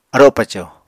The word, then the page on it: aro paco: Apatani